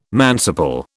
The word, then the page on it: manciple: British English